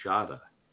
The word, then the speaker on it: Shada: K-U-R-T. – Yeah. – Kurt, and it was Shada. My last name was real, old German name. They pronounced it right in the class, Shada. And then one time, the principal came on the inner class, that is Jim Shade there.